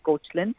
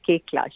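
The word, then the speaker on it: Koechlin: I mean, you know, it's not like her spelling is weird, but it's K-O-E-C-H-L-I-N, so even I used to say Kuchlin. Okay. And then before the world premiere Toronto, Cameron Bailey said, how do we say her name? And that's when it was revealed to us, it's Kekla. She trained both him and me. It's actually a French name, that's why.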